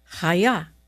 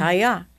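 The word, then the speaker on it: Chava: And by the way you pronounce her name in the Bible is Chaya. Isn't that pretty? – Mm-hmm. – Chaya.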